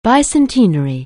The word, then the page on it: bicentenary: anglais